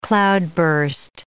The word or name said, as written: cloudburst